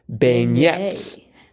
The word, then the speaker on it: beignet: And then that the N of Menti was in the middle of 88 down fried pastries popular in New Orleans, beignets. – Yes. – Is that how it's pronounced? Beignet? Okay. Mm-hmm. That makes sense. More sense than beignet.